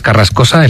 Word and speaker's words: Carrascosa: Pero mira, sí, eso le quería preguntar yo a Elena Carrascosa. Ella nos quita los dolores y nosotros le quitamos sílabas a su apellido. He dicho Carrasco y es Carrascosa. Elena Carrascosa, perdónanos.